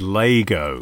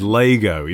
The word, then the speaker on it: Lego: Although, I should say right up top that the official pronunciation, which I'm not expecting us to stick to, but it needs to be said, is Lego. It is Lego, even though no one does.